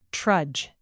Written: trudge

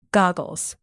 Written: goggles

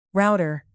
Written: router

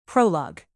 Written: prolog

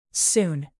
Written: soon

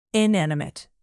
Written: inanimate

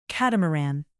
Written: catamaran

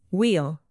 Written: wheel

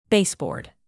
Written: baseboard

